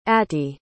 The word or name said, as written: atty